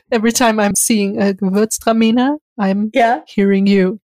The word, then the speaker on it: Gewürztraminer: But my next order from Wink Wine is going to be, or is going to contain, Gewürztraminer. – That's how you say it? – Gewürztraminer.